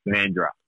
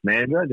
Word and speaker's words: Mandurah: Mandurah. – You're going Mandurah. – Being a sandgroper now, it is just Mandurah.